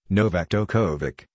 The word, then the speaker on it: Novak Djokovic: Novak Djokovic, Serbian Cyrillic, romanized, Novak Djokovic, pronounced, Novak Djokovic, listen, born May 22, 1987, is a Serbian professional tennis player.